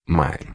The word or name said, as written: maio